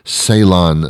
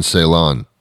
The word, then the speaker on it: Ceylon: but there's a certain type of cinnamon only that has those health benefits to that extent, and that's Ceylon cinnamon, like Celine Ceylon. C-E-Y-L-O-N, I'll repeat it for the hard of hearing, C-E-Y-L-O-N, Ceylon, I think is how you pronounce it, cinnamon.